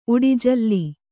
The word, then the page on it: ಉಡಿಝಲ್ಲಿ: uḍi jhalli